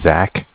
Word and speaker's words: Zach: My name is Zach, spelled Z-A-C-H. – Okay. – I'm calling from Bank of Cardiff. Cardiff is spelled C-A-R-D-I-F-F.